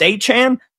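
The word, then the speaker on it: Achane: Achan, how do we say his name? We don't know. Nobody knows. Oh, it's Achan.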